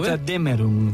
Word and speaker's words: Götterdämmerung: Götterdämmerung.